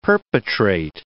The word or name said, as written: perpetrate